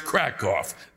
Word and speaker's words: Krakow: There are no cows here in Krakow. It's pronounced Krakow.